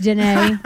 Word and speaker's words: Danae: It's pronounced Danae. The accent is not there.